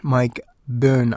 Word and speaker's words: Byrne: And the former All Blacks and Wallabies skills coach, Mike Byrne, I'm told is the pronunciation, has been appointed as the new head coach of Fiji.